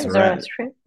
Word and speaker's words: Zoroastrian: Yeah, Zoroastrian.